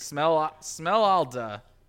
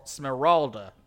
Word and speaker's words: Smeralda: What is that R doing there? That R is wrong. Smeralda. Okay, there it is. I got my tongue to move the right way.